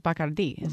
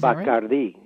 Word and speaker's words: Bacardi: And they would have pronounced it Bacardi, Bacardi, yeah. Mm-hmm. It's become sort of anglicized now.